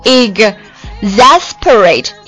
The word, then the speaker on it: exasperate: 比较拗口的啊，比较拗口的是那个 S 的发音啊，我们一起来读一下 exasperate, exasperate, 会很不啊，就是一下子就把这个 S 就是会跳过去啊，读的时候 exasperate。我第一遍看这个单词的时候，我读的时候我老是读不过来，我会读作 exasperate, exasperate, 其实它还有个 S 在中间呢 exasperate, exasperate。好，来一起看一下这个单词的字母 E-X-A-S Exas 后面 P-E-R-A-T-E Parade Exasperate 这个单词啊，什么意思呢？